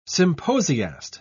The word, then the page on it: symposiast: British English